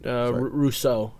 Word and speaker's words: Rousseau: The art is going to be Craig Ruscio? – You're moving... Rousseau. – Yeah, Rousseau. – It's pronounced with... it's the French pronunciation, though.